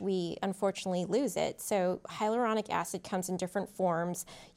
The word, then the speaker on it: hyaluronic acid: We're hearing a lot about hyaluronic acid. I hope I'm saying that right. – Yeah, you said it perfectly.